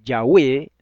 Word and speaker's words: Yahweh: Con acento en la E. Es una palabra de dos sílabas y es una palabra aguda. Entonces es Yahweh, no es Yahweh.